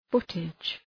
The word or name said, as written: footage